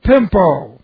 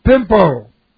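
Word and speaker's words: pimpo (πέμπω): The word to send here is the future indicative of the word pimpo. P-E-M. P long O, pimpo.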